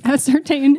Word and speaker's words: ascertain: I know that the correct pronunciation is ascertain, and then I went and sat back down.